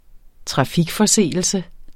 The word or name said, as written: trafikforseelse